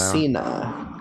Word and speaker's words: Cocina: Over at I like saying Cochina Cucamonga. It's probably not even how you say it. I don't know. – Cocina.